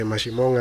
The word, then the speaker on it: Maximón: Pues una de las teorías es de que cuando esta tradición llegó a las personas ladinas, la forma en que lo pronuncian pues, los nativos no es igual y pues los confundía. Entonces se empezó a hacer esta transición de Mashimón a San Simón, Mashimón, San Simón, Mashimón, San Simón.